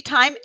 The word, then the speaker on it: Tyme: Is it Tyme, right? Am I saying that right? Tyme? – It is Misty Tyme.